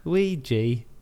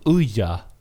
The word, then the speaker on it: Ouija: Jag, jag uttalade det som Uja.